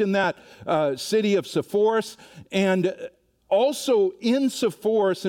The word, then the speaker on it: Zippori: Well, we know that the closest city to Nazareth, where he grew up, was a place called Sepphoris or Zippori, uh, would be the Hebrew way of pronouncing that.